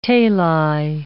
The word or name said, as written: tali